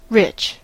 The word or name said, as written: rich